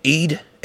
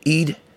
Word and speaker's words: Eid: Is it Eid? Aid? AIDS? Aid for Mubarak AIDS. That's not how you say it. It's Aid, Eid, Eid.